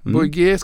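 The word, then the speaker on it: Bouygues: Sen har vi då ett företag som heter Bouyg, eller Bouygué. – Bouygué, ja. Mm. – Bouygué ska man uttala det.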